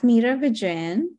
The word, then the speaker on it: Meera Vijayann: If you do at Meera Vijayann, you should get my Instagram, and my name is spelled MEERAVIJAYANN. That's a mouthful, but but it spells just how it sounds.